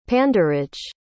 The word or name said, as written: panderage